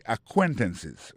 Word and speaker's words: acquaintances: Et puis, en même temps, de toute façon, côté accent tombé dans moi, c'est acquaintances. Hein? Accent tombé sur deuxième syllabe là.